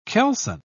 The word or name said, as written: kelson